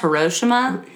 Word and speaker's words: Hiroshima: It's pronounced Hiroshima.